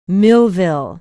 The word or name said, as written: millville